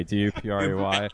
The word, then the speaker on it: Dupre: My last name is spelled Dupre. Dupre.